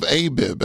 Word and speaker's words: Abib: So, Chris, they called that month the month of Abib. That's spelled A-B-I-B, which means something sprouting, something budding.